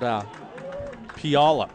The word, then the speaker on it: Puyallup: Pileup, I think that's how you pronounce it. – No, it's called, not Pileup, it's called Puyallup. – Puyallup, thank you.